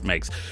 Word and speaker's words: Meeix: So on Twitter, Makes tweeted, this is M-E-E-I-X, but she, you know, says it's pronounced Makes.